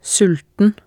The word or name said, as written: sulten